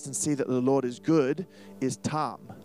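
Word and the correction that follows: ta'am: or sorry, toam. I said that wrong. So it is a verb, phonetic spelling. Say toam.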